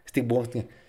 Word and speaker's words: Steve Wozniak: Steve Wozniak, que otra vez, Steve Wozniak, ya no lo vuelvo a decir.